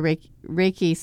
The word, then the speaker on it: Reiki: And they said they wanted to put their Reiki service on.